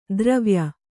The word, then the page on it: ದ್ರವ್ಯ: Kannada; dravya